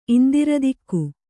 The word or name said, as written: ಇಂದ್ರದಿಕ್ಕು